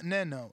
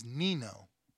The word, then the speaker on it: Nino: It's not Neno. It's Nino. What up? – Nino, like the casino, champ.